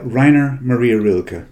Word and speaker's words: Rainer Maria Rilke: Will you pronounce it for me? – Yeah, Rainer Maria Rilke.